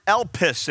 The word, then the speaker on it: elpis: Last week, the word for hope is the Greek word elpis.